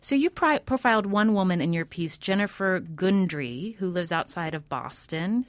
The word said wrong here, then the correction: Guidry: Am I pronouncing her last name correctly? – It's Guidry. – Guidry.